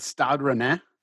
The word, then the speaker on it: Stade Rennais: Was it Stade Rennais? – It's yes, but. You know, pronounce it properly. Sorry, I don't mean to be pretentious here. No, you've done a good job there, mate.